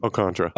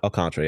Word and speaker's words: Alcantara: However you say, I always mess up his last name. – Alcantara. – Alcantara, yeah. It's spelled Alcantara, but it's pronounced Alcantara.